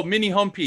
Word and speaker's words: Minihomepy: Mini Home Pie. Do you think it's Mini Home Pie or Mini Home? My wife told me it was Mini Home Pie.